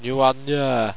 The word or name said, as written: nuanaa